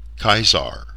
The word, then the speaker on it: Caesar: As well, we've tended toward the ancient Latin hard C, since they never had a soft C, Caesar, not Caesar, as most have heard the name.